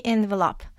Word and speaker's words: envelope: 이 그 봉투라는 의미가 있는 명사로 이 envelope 발음을 보면요. envelope, envelope. envelope, envelope. 이렇게 소리가 나더라고요. envelope, envelope. 동사에서는 envelope, envelope 이렇게 되는데 지금 동사로 쓰인 건 아니고 명사로 쓰였기 때문에 envelope, envelope 이렇게 하시면 될 것 같아요.